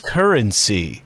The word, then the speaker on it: KRNC: CEO of KRNC, or pronounced currency.